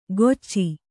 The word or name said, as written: ಗೊಚ್ಚಿ